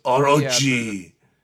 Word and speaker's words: Orochi: And this one is based on a silent film, actually, called, like, Orochi or something like that. Is that how it's pronounced? Orochi.